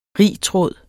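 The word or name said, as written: RITRÅDEN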